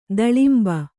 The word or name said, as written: ತಿಲಾಂಬು